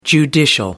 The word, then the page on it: judicial: American English